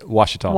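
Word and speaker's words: Ouachita: Because he saw this news today and he says, how do you say this name? O-U-A? I'm like, Wachita.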